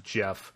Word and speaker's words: Gef: But according to them, at least, it wasn't always named Jeff, which is spelled G-E-F, by the way.